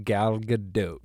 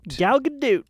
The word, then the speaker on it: Gal Gadot: It's pronounced Gal Gadot. – Gal Gadot.